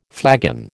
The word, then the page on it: flagon: American English